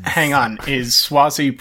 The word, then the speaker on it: Suozzi: Spell his name with a W, even though it's pronounced Suozzi, so yeah.